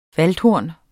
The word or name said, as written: VALDHORNET